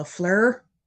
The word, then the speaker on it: FLIR: A FLIR? Am I saying it right? F L I R.